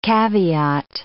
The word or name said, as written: caveat